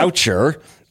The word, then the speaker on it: Gaucher: I want to point out that you have a, a disease that I consider the Stephen Colbert disease because it looks different than it's pronounced and it's quite French. Gaucher yeah. looks like yeah. Goucher.